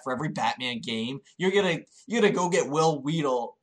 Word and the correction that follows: Friedle: Wait, is that his last name? Am I saying that wrong? – Oh, right off. – Friedle.